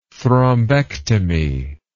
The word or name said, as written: thrombectomy